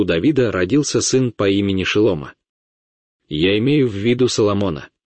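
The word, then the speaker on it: Шело-мо (שלמה): Его настоящее имя — Шелома, но вы, ребята, к сожалению, называете его Соломоном. Но я говорю о том, что когда я произношу его имя Шелома, вы, ребята, знаете иврит, когда я говорю Шелома, вы слышите это слово. Шело-мо. Шело-мо.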